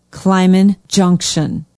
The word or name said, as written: Clyman Junction